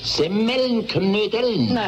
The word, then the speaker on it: Semmelknödeln: Jetzt hast du nämlich gerade gesagt Semmelknödeln. – Ist recht, Semmelknödeln. – Nein. Richtig muss es eigentlich heißen Semmelknödeln. Die Semmel muss man dabei betonen, weil doch die Knödel aus Semmel gemacht sind.